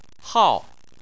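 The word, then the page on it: hao: tone 4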